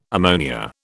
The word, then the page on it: ammonia: British English